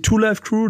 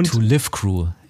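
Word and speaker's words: 2 Live Crew: die Two Life Crew, nicht die Two Life Crew. Die Two Live Crew, ja.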